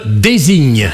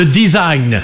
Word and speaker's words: design: désigne. Alors je sais qu'il vaut mieux prononcer à la British, hein, le design. Parce que si on dit désigne, bah, on passe pour un plouc.